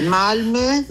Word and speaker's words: Malmö: Allora, la pronuncia sì. È Malmö, Malmö, non è la capitale, quella è Stoccolma.